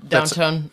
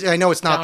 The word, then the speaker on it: Downton: Downton. – Downton. – Yeah, I know it's not Downtown.